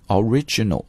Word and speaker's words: original: 当然呢它的形容词呢就发生了一个重音的迁移，就会把它读成 original, 所以 original origin 啊，重复一下把它记住。